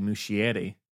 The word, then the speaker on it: Muschietti: One thing I will say that I don't think is being appreciated enough about Andy Muschietti, that's how you say his name, is he'll feel appreciated.